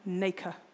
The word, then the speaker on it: nacre: It has to apply layer upon layer of, I looked up the pronunciation, nacre, N-A-C-R-E,